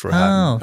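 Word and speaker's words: Rohatyn: You know, in the movie, they say it a couple of different ways, but I sort of make it rhyme with Manhattan, Felix Roehatyn.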